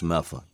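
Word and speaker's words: Medford: So, wait, for those listening in other countries, that's M-E-D-F-O-R-D, pronounced Meffa.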